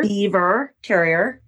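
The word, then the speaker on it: Biewer Terrier: We have the, you say I pronounce it more like Beaver. – Beaver Terrier, yes. Just a cute little long-coated dog of multicolors, gold and white and tan, that has only a ponytail.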